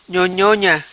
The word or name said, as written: Nhonhona